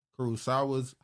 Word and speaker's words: Kurosawa: Denzel Washington and Spike Lee reuniting to remake Kurosawa's High and Low with A24 and Apple. – Kurosawa, that's how you say it.